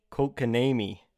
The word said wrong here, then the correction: Kotkaniemi: Jesperi Kotkaniemi.